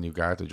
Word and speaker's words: Ugarte: Ugarte.